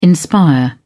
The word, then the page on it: inspire: British English